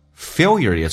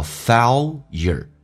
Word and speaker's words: failure: Failure is your best teacher，红色字体用降调读。第一个单词美式发音叫 failure，英式发音叫 failure，它把 L 提前发了，跟 is 而且有一个连音叫 Failure is your 降调读红色字体。